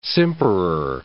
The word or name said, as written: simperer